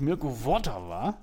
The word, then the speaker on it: Mirko Votava: Oh, und das ist auch noch so ausgesprochen, wie ich es noch nie in meinem Leben gehört habe, aber vermutlich eben richtig. Mirko Votava, so auf der ersten Silbe, stark.